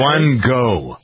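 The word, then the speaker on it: One Goh: One Go. – Yeah. – Is that how you pronounce it? O-N-E, one go, G-O-H. – One go.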